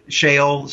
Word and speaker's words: Sheol: Yes, we know that they went, Matt, to Sheol. S-H-E-O-L is how it's spelled in English, the Hebrew word, Sheol.